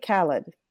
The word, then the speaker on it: Khaled: And on the 26th, DJ Khaled.